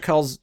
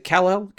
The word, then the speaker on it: Calle: It was said to have Sasha Calls, is it Kal-El? How do you say her last name? Sasha Calls? Calls? – I mean, it's not Kal-El, that's Superman. – Yeah. – Would you say it was C-A what? – C-A-L-L-E.